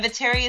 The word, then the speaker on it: Viteri: And Viteri is spelled V as in Victor, Iteri.